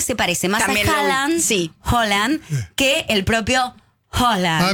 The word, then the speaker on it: Haaland: Que en noruego se pronuncia Haaland.